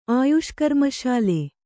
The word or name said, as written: ಆಯುಷ್ಕರ್ಮಶಾಲೆ